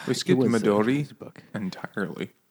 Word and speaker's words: Midori: I think it's just Midori, and you just flip the R sound.